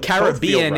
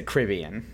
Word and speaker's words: Caribbean: So Caribbean is the traditionally British pronunciation, which explains why they're saying it that way in the Pirates of the Caribbean movies. – Ain't nobody more British than Keira Knightley. – Now the Americans say Caribbean.